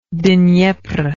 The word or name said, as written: dnepr